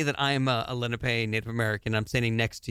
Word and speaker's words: Lenape: I think I'm pronouncing that correctly. The Lenape? – Lenape.